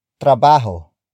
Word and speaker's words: trabaho: Nasa is spelled as N-A-S-A. It means located at. The next word is trabajo. Trabajo. Trabajo. Trabajo is spelled as T-R-A-B-A-H-O.